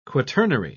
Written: quaternary

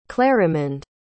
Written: clarimond

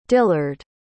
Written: dillard

